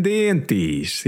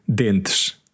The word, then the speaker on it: dentes: The yeah, the people from Alentejo, they often say dientes. – Yeah, the e's at the end of words that for us would be silent, not pronounced. For them, are like eyes, like the Brazilian, really. – Yeah, but the word, you know, a person in Lisbon would probably say dentes, but she said dientes.